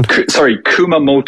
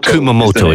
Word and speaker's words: Kumamoto: Kumamoto. – Kumamoto, excuse me.